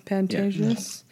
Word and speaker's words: Pantages: It's the Pantages. Is that how you say it? Or the, yeah. Pantages. Yeah.